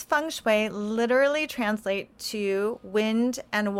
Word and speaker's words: feng shui: So the whole term is, the proper way to describe feng shui is to say feng shui, because that's how it's said in China.